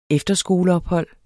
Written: EFTERSKOLEOPHOLD

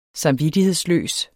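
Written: samvittighedsløs

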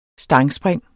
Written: stangspring

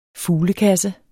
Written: fuglekasse

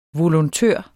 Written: volontør

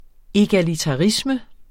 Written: egalitarisme

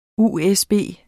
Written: USB

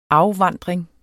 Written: afvandring

